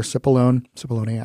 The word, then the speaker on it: Cipollone: Mr. Cipollone, I think is actually the proper pronunciation.